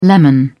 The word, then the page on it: lemon: British English